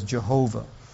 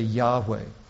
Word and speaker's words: Yahweh: Some people pronounce it as Jehovah. But it is probably more correct to say Yahweh.